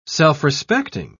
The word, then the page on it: self-respecting: British English